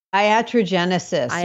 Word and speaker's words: iatrogenesis: And you use a Greek phrase, atrogenesis. Is that how you pronounce it? – Iatrogenesis. Yeah,